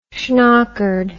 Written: shnockered

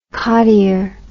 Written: cottier

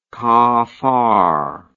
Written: cafard

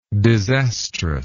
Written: disastrousness

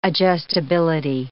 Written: adjustability

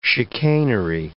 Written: chicanery

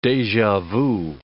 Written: deja vu

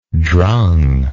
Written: drung

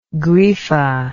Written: griffa

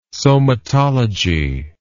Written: somatology

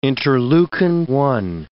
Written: interleukin-1

